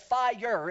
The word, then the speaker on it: fire: And he pronounced it fire. It was two syllables.